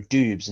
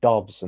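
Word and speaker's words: Doubs: I thought it was Romeo Dubes, and now it's Romeo Dobbs,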